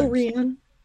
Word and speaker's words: Ile-Rien: Uh, Ile-Rien.